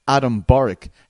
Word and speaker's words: Borics: It's undefeated prospect and Bellator featherweight, Adam Borics. How are you, my friend? – Hey, I'm good. You? – Did I get the pronunciation right? Did I pronounce the name right? – Yeah. – Yes.